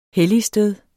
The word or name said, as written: helligsted